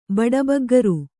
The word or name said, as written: ಬಡಬಗ್ಗರು